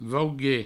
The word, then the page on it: vauguer: vaugue